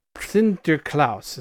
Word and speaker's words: Sinterklaas: Sinterklaas is how you actually pronounce it.